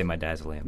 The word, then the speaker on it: midazolam: I can never tell how to pronounce these. – That's right, medicines. – Yeah. We say midazolam.